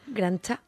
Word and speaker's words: granja: ¿Cómo se pronuncia? – Grancha. – ¿Grancha? – Bueno, Grancha, yo porque yo soy de Gandía y hablo con la X así, Grancha.